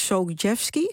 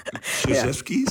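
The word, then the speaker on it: Krzyzewski: Op een gegeven moment wordt het team dan begeleid door Mike Sogjevski. Zeg ik dat goed? Coach K, misschien makkelijker? – Coach K, ja, dat is een hele moeilijke naam. Daarom noemt iedereen hem ook altijd Coach K. Sogjevski, zeggen ze in het Engels.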